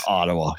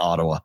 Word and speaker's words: Ottawa: completely the wrong syllable. – It was driving me nuts. – Ottawa? – Ottawa is what they were saying on the broadcast. I was watching the Ottawa broadcast, and that's what they were saying. Here in Ottawa, I was like, why are you saying it like that? That's really, Ottawa. Yeah, Ottawa. No, it's Ottawa. – Ottawa? – Yeah, Ottawa. I guess that's the way you're supposed to say it. – With an accent on the second syllable.